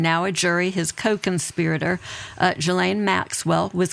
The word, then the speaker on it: Ghislaine: So it's French. It is Ghislaine. Ghislaine with a hard G. I think if you were really trying to speak it in French, it would be Ghislaine, but I think just saying Ghislaine for our purposes is fine.